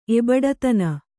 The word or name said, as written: ಎಬಡತನ